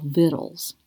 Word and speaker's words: victuals: And the name of her latest cookbook that has won this award is called Vittles. It's spelled V-I-C-T-U-A-L-S. And she spells her last name L-U-N-D-Y.